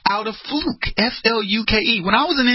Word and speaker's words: Fluke: It's spelled Fluke, but people said her name was Fluck. And I'm going, seriously? Her name is Fluke. How do you get Fluck?